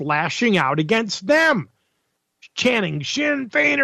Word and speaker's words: Sinn Féin: All right, Sinn Féin, it's spelled S-I-N-N-F-E-I-N, but it's pronounced Sinn Féin.